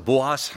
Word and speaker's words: Boas: Ich habe es manchmal jetzt die letzten Male falsch gesagt, Boas, ich habe nochmal nachgeguckt, das schreibt sich nicht mit Shin. Also Boas heißt der Mann.